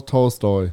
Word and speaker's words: Tolstoy: It, you want you want to bring in Toy Store or how do you say his name? – Tol- Tolstoy.